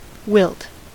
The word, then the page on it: wilt: American English